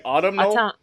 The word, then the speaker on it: autumnal: Autumnal. That's how you say it, right? Autumnal? – Autumnal.